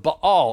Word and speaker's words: Baal: And so what happens is it looks a little different, but what it actually is house of Baal or house of Baal, depending on how you've heard that pronounced.